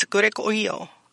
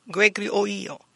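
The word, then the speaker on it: grēgoreō: And it's Gregorio. Gregorio. Gregorio.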